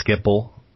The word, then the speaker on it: Schiphol: Somebody told me is it pronounced Schiphol, the airport? – It's Schiphol, basically.